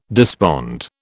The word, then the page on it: despond: British English